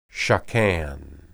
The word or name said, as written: shakan